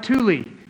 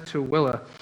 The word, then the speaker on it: Tooele: We're on the other side of the mountain, and it is spelled like Thule, but we pr- pronounce it as Tooele.